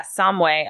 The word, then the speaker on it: Samhain: Yeah, so it's spelled S A M H A I N. – Yeah, but she kept saying Samway. – I think you're right, Samway.